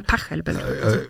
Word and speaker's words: Pachelbel: Pachelbel. Betonung auf Pach.